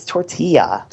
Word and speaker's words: tortilla: It's tortilla. – Tortilla, but there's no Y in it. – No, but the L's, two L's together make a Y.